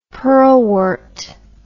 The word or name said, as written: pearlwort